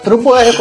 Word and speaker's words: Turbo R: Turbo R, como diz no manual. – Turbo é. R. É, é o, que o manual diz a pronúncia certa em japonês.